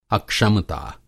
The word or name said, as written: अक्षमता